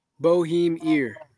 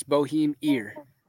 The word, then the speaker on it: Bohemier: Bohemier. – Okay, Bohemier. Bro, because I've been wondering too. I've been calling. – everybody say Bohemer or Boheimer. It's Bohemier.